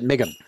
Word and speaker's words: MGM: Now, if you're in the film industry, as I have been, they pronounce it Migum.